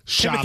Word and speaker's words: Chalamet: You never once managed in that segment to say it correctly. You said sham. You said charm. You said... – It's confusing, though. – Timothy what? – Well, now I know it's Chalamet. I know it's like the whole... – Okay, so yeah, well, that's three syllables, but let's put them all together into a word.